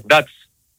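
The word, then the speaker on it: DAC: Θέλω να βοηθήσετε πώς προφέρεται η ομάδα της Σλοβακία. Ντάτς. – Ντάτς. – Ντάτς, Οκ. – Ντάτς χωρίς καρπούς.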